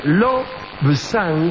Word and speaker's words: Lobsang: Se omite una B que no se escribe, pero que se pronuncia en casi todas partes. Sería Lobsang, ¿eh?